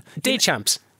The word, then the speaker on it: Deschamps: You don't pronounce the S at the end of it, is it? It's just Deschamps.